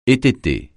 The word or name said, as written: étêter